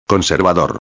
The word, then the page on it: conservador: espanhol